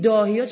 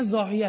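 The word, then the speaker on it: ضاحیه: این داهیا چون انگلیسیشو خوندن عزیزانی که مثلا حالا یک جایی مطرح کردن نمیدن، این همون واهیه است، چون عرب با وا اینطور تلفظ میکنه اینا د میشنون، یعنی انگلیسیشو د میگه مینویسن. خب این داهیا نیست، دکترین زاهیه است.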